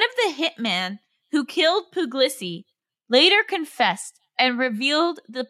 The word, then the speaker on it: Puglisi: And so this one post is about, I can't pronounce this man's name, but it's about Pino Puglisi? – Giuseppe Pino Puglisi.